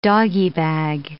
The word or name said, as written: doggy bag